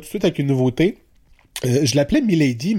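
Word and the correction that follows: Maélyde: mais c'est pas comme ça qu'il faut prononcer son nom d'artiste. C'est Miley. Donc Maelyde, accent aigu E.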